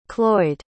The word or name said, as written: Cloyd